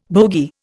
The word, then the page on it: bogie: Indian